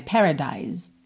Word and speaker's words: Paradise: No caso de Paradise, a gente termina nesse som de S que acaba soando como um Z, né? Paradise, Paradise, ok?